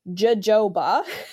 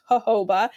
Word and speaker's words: jojoba: It looks, the word looks like jojoba, to most people, but it's pronounced jojoba, and it's very similar to human sebum, which is a substance that our skin actually produces to help protect our skin.